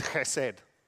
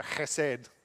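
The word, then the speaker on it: chesed (חסד): So the way you pronounce this is chesed. So chesed.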